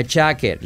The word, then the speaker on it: jacket: For my jacket. Letra clave J. Jacket. La T final casi no se dice. Jacket.